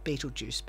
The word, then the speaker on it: Betelgeuse: It's pronounced and often spelt Beetlejuice, but it's like Betelgeuse, it's like in German or something.